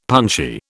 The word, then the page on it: punchy: British English